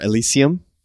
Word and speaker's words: Elysium: I went back to Elysium, or Elysium, depending on how you want to pronounce it.